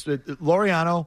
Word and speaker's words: Laureano: Oh, isn't it like Laureano? – Laureano, yeah. You got it.